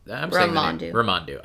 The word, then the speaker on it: Ramandu: That is an interesting point, because when they get to Esmandu's... Ramandu. I said his name wrong before.